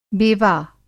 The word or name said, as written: बेवा